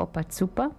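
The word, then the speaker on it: Opa Cupa: Quindi se fate una ricerca su Google, Oppa Zuppa, che praticamente si scrive Oppa Cupa, però si pronuncia Oppa Zuppa, troverete un sito con le parole e la musica, molto interessante.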